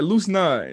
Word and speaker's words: Luos Nod: The first time I played your music around my girl and it came up, she was like, how do you pronounce that? I was like, Luos Nod. And she looked at it, she said, are you sure? I was like, yeah, that's what he said.